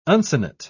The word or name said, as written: uncinate